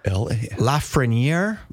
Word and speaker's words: Lafreniere: And then help me with this pronunciation of Joe's last name. He owns this liquor store in Preston. – Okay. – It's LAFRENIERE. – Lafreniere.